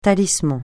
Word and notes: talisman: francais

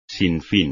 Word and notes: sinfín: espagnol

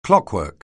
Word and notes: clockwork: anglais

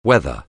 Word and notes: whether: anglais

